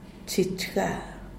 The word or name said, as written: chich ga